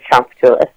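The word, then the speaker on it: Tchoupitoulas: Most of the way up and down New Orleans called Champatoulis, which is spelled wonderfully, but his name is Champatoulis.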